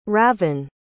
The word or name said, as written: ravyn